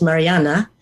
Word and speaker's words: Marianne: And we started chatting, and she told me her name was, again, when you know her, her name was Mariana, the Norwegian pronunciation.